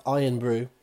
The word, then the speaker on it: Irn-Bru: Iron Brew, which is spelled I-R-N-B-R-U.